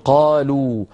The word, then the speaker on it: قالوا: ولا تنطق هذه الواو، تكتب ولا تنطق، لا ينطقها أحد، ما في أحد يقول قالوا مثلا، كل الناس تقول قالوا، مع أنهم يكتبون ألفا بعد واو الجماعة ليبينوا بأن هذه الواو واو جماعة وليست من أصل الفعل.